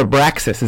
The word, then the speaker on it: Abraxas: We pronounce it Abraxas. Is that how you pronounce it, Carlos? – Yes, exactly.